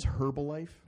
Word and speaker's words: Herbalife: Well, one of my favorite shorts recently was Bill Ackman shorting Herbalife, and no one says Herbalife. It's a silent H. – Herbalife. – Yeah. – Oh well, I'm a moron. Why not pronounce the H?